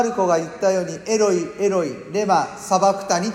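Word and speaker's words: エロイ エロイ: イエス様はおそらくエロイエロイと発音してるんですけれども、別に間違いじゃないです。それをエロイエロイをヘブル語に訳せばエリエリになりますから、全然間違いではないんですけれども、でも当時イエス様が発音されたのはエロイエロイだと思うんです。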